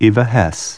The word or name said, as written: Hesse